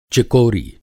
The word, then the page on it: चिकोरी: Hindi